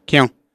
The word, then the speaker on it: Keown: A really interesting take by Tim, how do you pronounce his name? Keown? – Yeah, Keown.